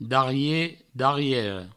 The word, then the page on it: darrier, darriera: Provençal; darrié, darriero